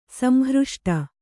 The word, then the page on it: ಸಂಹೃಷ್ಟೆ: samhřṣṭhe